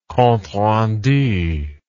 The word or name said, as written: compterendu